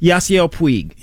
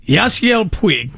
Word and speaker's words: Yasiel Puig: How do you say his name? – Yasiel Puig. – Yasiel Puig. Okay.